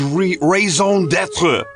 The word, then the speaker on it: raison d'être: Like children, these two musical loves are his raison d'être, the soundtrack to his personality.